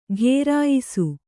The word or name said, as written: ಘೇರಾಯಿಸು